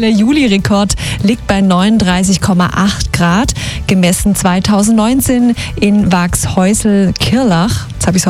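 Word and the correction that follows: Waghäusel-Kirrlach: Waghäusl-Kirlach, ohne S, gell?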